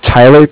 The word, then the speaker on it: Tyler: My name is Tyler, T-Y-L-E-R, and I'm calling with Cardiff. It's spelled C-A-R-D-I-F-F, as in Frank Frank.